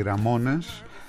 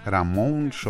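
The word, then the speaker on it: Ramones: Οι Ραμόνες, Ραμόνς όπως θέλετε την προφορά, έχουν μια συναυλία στο κλαμπ CBGB στο Μανχάταν της Νέας Υόρκης.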